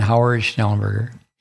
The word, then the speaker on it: Howard Schnellenberger: Yeah, there you go. I said it wrong. Oh, then, okay. That's the gentleman. Say it again. Howard Schnellenberger.